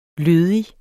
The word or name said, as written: lødig